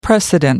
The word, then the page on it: precedent: American English